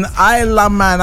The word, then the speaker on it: Ila Man: The name is Isla Man. Isla. I-L-A-M-A-N. Isla Man.